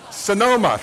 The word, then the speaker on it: Sonoma: Sonoma, thank you.